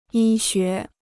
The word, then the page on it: 医学: yī xué